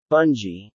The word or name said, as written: bungee